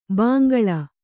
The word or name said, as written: ಭೋಂಕಲ್